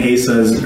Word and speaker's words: Asa: there were certain ministers that used the word or, or pronounced it Asa instead of Asa. And I'm just going to set the record straight at the beginning here. We're going to go with Asa. It's just what I've been used to saying, and, and hopefully for you as well. Asa is the pronunciation we're going to go with this morning.